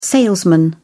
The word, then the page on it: salesman: British English